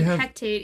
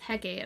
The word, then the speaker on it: Hecate: I always pronounce it Hectate, even though it's Hecate, and I don't know why I do that.